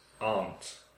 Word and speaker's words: aunt: You say it the same as me. Mm. Aunt. The vowel sound R, like car. Aunt.